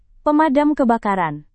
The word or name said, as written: pemadam kebakaran